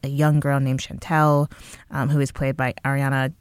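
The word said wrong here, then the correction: Ariyan: Ariane Johnson.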